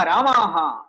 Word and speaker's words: रामा: पुल्लिङ्गे चेत् रामः दीर्घं कुर्मः चेत् रामा इति भवति अतः रामशब्द उच्चारणसमये श्रद्धा करणीया रामः वा रामा वा इति चिन्तनीयं दीर्घीकृत्य वदति चेत् महिला इति अर्थः